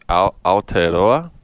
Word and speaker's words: Aotearoa: So he was from, I will have trouble pronouncing it myself, but it's Aotearoa.